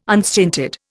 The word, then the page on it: unstinted: Indian